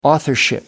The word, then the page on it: authorship: American English